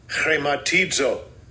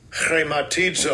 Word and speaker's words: Chrematizo: This is how you pronounce it. – Strong's G5537. Chrematizo. Chrematizo. – Okay, now we're going to try to pronounce that. It's on the back of the throat.